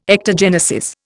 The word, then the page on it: ectogenesis: Indian